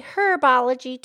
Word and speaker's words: herbology: Yeah, it's proper to say herbology, too. Either is correct. Martha Stewart will tell you, you could pronounce the H or you do not have to pronounce the H.